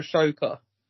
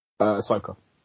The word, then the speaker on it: Ahsoka: Is her name Ahsoka or Ashoka? – Ahsoka. – Oh, sorry, I always say it wrong, don't I? Ahsoka. – No, I'm curious. I thought I'd been saying it wrong all this time. – I don't know why I'm putting a H in there. – There is a H in there, but it's before the S.